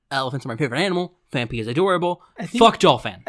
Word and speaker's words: Phanpy: Fanpi, actually, it's Phanpy. – Okay. – But a lot of people do say Fanfi. – Okay. – And I do too sometimes, but then I remember how it's spelled.